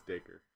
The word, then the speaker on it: Dacre: So it's Dacre.